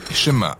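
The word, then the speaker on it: schéma (שמע): Le premier mot du schéma est « écouter » ou « entendre », prononcé « schéma » en hébreu.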